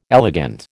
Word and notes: elegant: American English

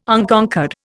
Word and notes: Unconquered: Indian

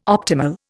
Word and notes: optimal: Indian

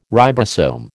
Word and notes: ribosome: American English

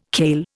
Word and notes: kale: Indian

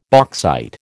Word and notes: bauxite: American English

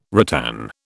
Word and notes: ratan: British English

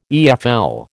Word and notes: EFL: American English

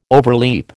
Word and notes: overleap: American English

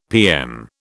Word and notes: PM: British English

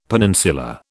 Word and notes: peninsula: British English

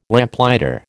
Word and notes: Lamplighter: American English

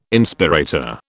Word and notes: Inspirator: British English

